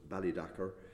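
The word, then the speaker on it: Ballydacar: The three survivors are Balach Dacar, now locally pronounced Bally Dacar, and Corabachla and Cluain Conan.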